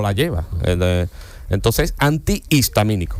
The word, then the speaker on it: antihistamínico: Y hay otra que también es así, digo que yo siempre la he dicho mal, que es antihistamínico. Antihistamínico. – Es verdad, sí. es cierto. – Lleva H intercalada entre las dos.